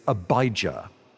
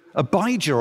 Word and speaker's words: Abijah: And to show how this sunk into Israelite consciousness, one of the really popular Old Testament names was Abijah. We usually pronounce it these days as Abijah. But Abijah or Abijah means the Lord is my father.